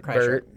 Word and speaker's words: Kreischer: Kreischer. It's not even hard. It's Kreischer. – It doesn't look like it's Kreischer. – No, don't look at it. Just say it. – Because Bert Kreischer.